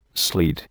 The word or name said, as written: sleet